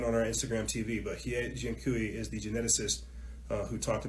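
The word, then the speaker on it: He Jiankui: Hie Jian Kui, you can spell that H-E space J-I-A N-K-U-I. Hie Jian Kui, yeah, go type it in.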